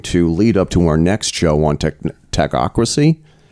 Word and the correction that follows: Technocracy: Is that how you pronounce it? – Technocracy. – Technocracy.